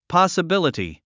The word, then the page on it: possibility: pɑ`səbíləti; パスィビリティ